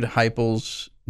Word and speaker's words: Heupel: I interviewed Heupel's junior college coach, Snow Junior College, for the school paper, and I asked him how to say it.